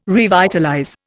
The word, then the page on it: revitalize: Indian